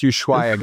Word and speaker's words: Ushuaia: It's pronounced Ushuaia, guys.